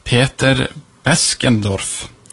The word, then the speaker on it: Peter Beskendorf: Peter Beskendorf. Jeg håper jeg uttaler det riktig. – Ja, det høres riktig ut det.